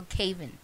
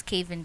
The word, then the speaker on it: Caven: Yeah, Kevin or Caven? – Caven. I'm quick. – Okay, cool. So who's Caven Deep? – Caven, I'm going to music.